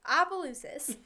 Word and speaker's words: Opelousas: Yeah, it's kind of, it's spelled weird, but I think it's Opelousas, Louisiana. – Opelousas? – Yeah, Opelousas. And he is basically known as the American boy who was lost and then found.